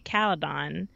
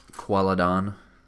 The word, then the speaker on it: Qalydon: And I'm trying to pronounce the word the way that they pronounce it in the show, which is like a QU pronunciation. Whereas before, I used to just say Caledon. But yeah, they have the little qua in there.